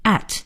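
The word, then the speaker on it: @: 有的同学读圈 A 花 A，这都不对。在英文里呢，应该是读成 at，就是我们新学的这个 at 这个字。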